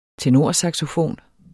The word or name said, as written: tenorsaxofon